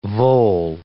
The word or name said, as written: vole